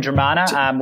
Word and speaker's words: Dromana: Is that, I pronounce that right, Dromana? – Yeah, so we're in Dromana.